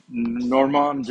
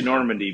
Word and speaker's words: Normande: And so we wanted to see what they had available, and they usually present their, the company Normande Genetics that I usually purchase my Normandy. I'm going to say Normandy because I'm an American. I know that the correct pronunciation of the breed is Normand, but I'm going to say Normandy from here on out because it's more comfortable for me as a Midwestern American.